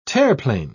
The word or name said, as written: terreplein